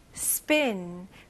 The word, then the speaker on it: spin: 이거는 I 발음입니다. 이거는 에, 에 이런 소리 나와야 돼요. spin, spin. 그리고 우리나라 사람들은 spin 이런 식으로 나눌 건데 영어로는 이름절로 spin, spin.